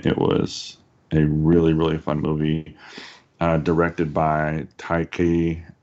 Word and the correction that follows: Taika Waititi: Is it Taika Waititi? – You got it. – Taika Waititi?